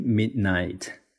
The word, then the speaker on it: midnight: 注意这个 midnight 凌晨午夜啊，它的发音这里呢，那个的呢被我给弱读了，这在英语里面是非常常见的一个高阶发音现象。如果你读成 midnight，midnight，那一听呢，就是可能英语水平不是很高的人啊，因为听起来非常土。我们要把的这个音给弱一下，直接是 midnight，midnight。